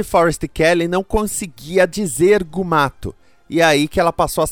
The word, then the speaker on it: Mugato: Se você olha os créditos, o Janus Prohaska, o ator, foi creditado como Gumato, o nome original. Por conta disso, todas as pronúncias são cânone. Gumato, que estava no roteiro, Mugato, que é como chamavam, além das formas erradas com o U, Mugatu, Gumato e Mugutu.